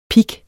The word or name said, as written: pig